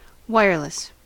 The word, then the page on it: wireless: American English